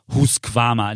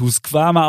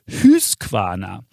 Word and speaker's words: Husqvarna: Es ging um die Rasenmäher-Roboterfirma Husqvarna, die natürlich nicht Husqvarna ausgesprochen wird, denn, so schreibt uns Alex, die Konsonanten im Schwedischen werden in der Regel wie im Deutschen ausgesprochen. Die Vokale aber, die klingen etwas anders. So auch das U, das wird fast immer wie ein Ü ausgesprochen. Es heißt also Husqvarna.